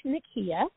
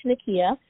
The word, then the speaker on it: Nikeya: So I'm on Instagram, and you can find me at Coach Nikeya. My name is spelled N-I-K-E-Y-A, so Coach Nikeya.